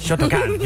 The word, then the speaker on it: Shotokan: Shotokan. You have to say it like that.